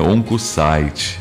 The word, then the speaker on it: Oncocyte: Onco se escreve site, né? Oncocyte, a gente pronuncia.